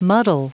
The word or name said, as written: muddle